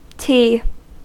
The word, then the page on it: ti: American English